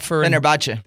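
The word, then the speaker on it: Fenerbahçe: Now he's playing for Fenerbahce. Help me out. – Fenerbahce.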